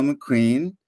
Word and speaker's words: MacQueen: I have a guest, Daniel MacQueen.